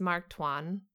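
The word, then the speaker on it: Tuan: I learned how to say his last name. – Okay, cool. – It's Mark Twan because I have been watching him a lot lately on playing Among Us with like Jay and like a bunch of other people.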